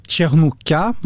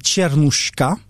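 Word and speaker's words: чернуха: Il y a un genre qui va apparaître, mais je vais certainement mal le prononcer, les tchernouka. Que sont-ils ? – Alors, tchernouka, plutôt bien prononcé à la manière française, ou alors si on le prononce à la manière russe, c'est tchernouchka. C'est bon, il y a vraiment plusieurs orthographes qui peuvent exister.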